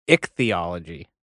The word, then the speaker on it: ichthyology: How do you say the, yeah, I didn't even want to butcher the word. I don't know if you guys got it. – It's ichthyology. – Ichthyology? – Yeah, I-C-H-T-H-Y. Ichthyology.